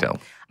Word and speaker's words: Bechdel: Yep, Bechdel.